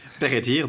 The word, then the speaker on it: Peredur: Ah, tu as dit Peredur, tu dois mettre un franc dans la jarre à prononciation galloise. – Peredur, donc, est associé non pas avec le Pays de Galles comme il est dans la tradition de Chrétien, mais avec le Nord.